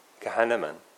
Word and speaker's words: Kahneman: Is that how you would say his name? Kahneman? Yeah. Kahneman? Kahneman. Okay, nice. Like Hanuman. Kahneman.